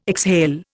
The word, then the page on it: exhale: Indian